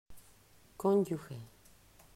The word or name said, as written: Conyuge